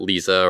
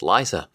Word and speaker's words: Liza: They pronounce it Lisa or Lisa, and whenever she gets her drink at Starbucks, she gets irritated with them. She's like, it's Liza. Like Liza with a Z.